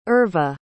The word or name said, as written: irva